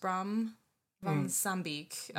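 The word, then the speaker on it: Bram van Sambeek: But with this CD that I believe his name is pronounced Bram von Sambeek has put out,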